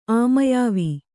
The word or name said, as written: ಆಮಯಾವಿ